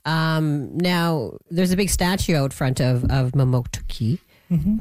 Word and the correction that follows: Mamotokati: Am I saying that right? Mamotoki? – No, Mamotokati. – Mamotokati. Oh, I missed something in there.